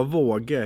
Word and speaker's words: Wauge: Ja, Våge helt enkelt. V-Å-G-E. Hur lätt som helst.